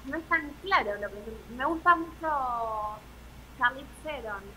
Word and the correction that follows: Charlize: Charlize Vamos a ver si, si, la, estamos pronunciando bien.